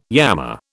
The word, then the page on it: yammer: British English